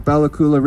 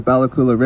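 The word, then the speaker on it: Bella Coola: And so we are the Nuchalkim, the people of the Balakula River. Balakula is in fact a mispronunciation by Captain Vancouver of the Heiltsukla word Bahwala, which means the people of the long inlet that becomes a river valley, a long river valley.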